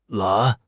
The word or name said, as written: lan2